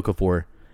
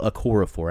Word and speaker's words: Okorafor: It's not Emeka Okafor, Emmanuel Okorafor.